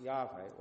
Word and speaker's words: Yahweh: sometimes pronounced Yahweh or Jehovah.